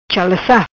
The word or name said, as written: Chalaca